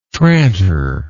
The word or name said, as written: tranter